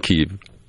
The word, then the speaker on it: Kiev: Kiev.